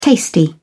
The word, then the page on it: tasty: British English